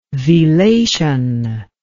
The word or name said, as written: velation